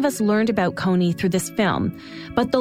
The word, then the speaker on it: Kony: There are, it has to do with linguistic differences in southern Uganda, why people call him Kony. – The proper pronunciation is Konya.